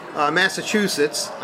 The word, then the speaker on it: Massachusetts: I want to get into your job, but first, I know you're from the East Coast, Massachusetts. I did say that correctly, right? – Yes.